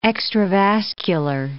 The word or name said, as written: extravascular